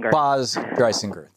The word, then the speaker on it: Baz Dreisinger: Baz Dreisinger.